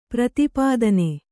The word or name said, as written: ಪ್ರತಿಪತ್ನಿ